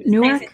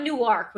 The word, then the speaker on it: Newark: Newark, like like it's pronounced like an Ark of the Covenant versus Newark, okay.